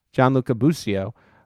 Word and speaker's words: Gianluca Busio: Added that most of the team is still in Kansas City with 17-year-old Gianluca Busio. Am I pronouncing that correctly? – Gianluca Busio.